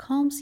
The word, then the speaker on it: calm: Going for a run calms you down. ال calm رو هم تلفظ نمی کنیم.